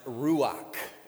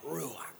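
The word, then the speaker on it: ruach (רוח): because the word Spirit is a Hebrew word pronounced ruach. It's that harsh guttural sound at the end, ruach.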